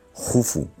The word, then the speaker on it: Jufu: Y eso lo aprovecha muy bien su hijo Keops, que por cierto, para cultura general, se escribe Keops, pero se pronuncia Jufu, y casi nadie le llama Jufu, porque Heródoto nos lo tradujo del griego y bueno, pues ahí se ha quedado, ¿no?